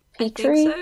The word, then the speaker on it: Petrie: is it Doug Petrie? – Petrie is how you say it? – Yeah.